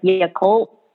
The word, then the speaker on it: Yakult: Like, I think that she introduced like Yakult. Is that how you say it? Is that yeah?